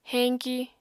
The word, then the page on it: heñki: Apatani